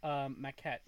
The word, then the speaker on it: Maquette: Maquette, yeah.